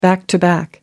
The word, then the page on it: back-to-back: American English